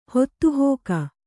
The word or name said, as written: ಹೊತ್ತುಹೋಗು